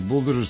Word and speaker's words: Buldožer: Buldožer s akcentom na O, buldožer, to je sad sam se ja, počeo sam polako da se gubim sa svim ovim glasovima, priznajem.